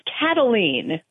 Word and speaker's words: Cataline: Actually, it's Cataline, like a cat.